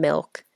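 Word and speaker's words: MILCK: So the example that Adam is asking about, one of my PR clients from last year is an artist named Milk, and she released her song Quiet.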